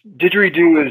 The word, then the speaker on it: didgeridoo: And am am I pronouncing it correctly? – You are. It's uh, in it's didgeridoo is a uh, it's actually it's an onomatopoeia.